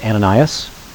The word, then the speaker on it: Ananias: In the New Testament, it's pronounced Ananias, his wife Sapphira.